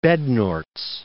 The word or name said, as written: Bednorz